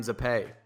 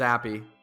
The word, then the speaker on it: Zappe: I, I want to pronounce his last name Zappe. I know it's Zappy, but it's Zappy.